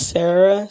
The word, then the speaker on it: Sarah: My name's Sarah, spelled with an H, do not forget that.